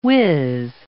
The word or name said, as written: wiz